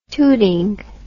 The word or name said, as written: tooting